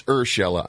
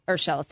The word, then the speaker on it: Urshela: Tell me if I'm saying his name right. Gio Urshela with the Yankees. – Sure. – Hunter Dozier with Kansas City. Rafael Devers. – It's Urshela, I'm told. – Urshela, thank you.